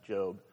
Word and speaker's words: Gob: One of the characters named Job, it's spelled G-O-B, but his character was named Job. G-O-B stood for George Oscar Bluth, in case there's trivia for you.